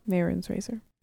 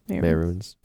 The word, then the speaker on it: Mehrunes' Razor: It was Horse Armor, Orrery, Wizard's Tower, Thieves' Den, Marunus's Razor, The Vile Lair. – Mehrunes' Razor. – Is that how you say it? – Mehrunes.